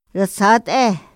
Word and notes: ra zat´e: Otomí

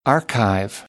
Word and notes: archive: American English

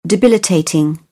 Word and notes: debilitating: British English; ɪ

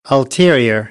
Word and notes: ulterior: American English

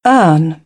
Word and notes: earn: British English; ɜː

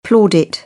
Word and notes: plaudit: British English; ɔː

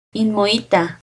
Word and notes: Inmoittah: Náhuatl

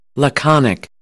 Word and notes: laconic: American English; ɑː

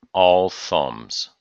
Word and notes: all thumbs: idiom

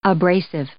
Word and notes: abrasive: adjective

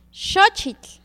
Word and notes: xochitl: Náhuatl

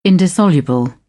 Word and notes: indissoluble: British English

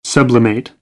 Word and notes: sublimate: American English; ə

